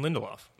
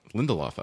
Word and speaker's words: Lindelof: I was nodding very intensely because I think that is Damon Lindelof. – Lindelof. Okay. Damon Lindelof.